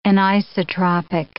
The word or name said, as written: anisotropic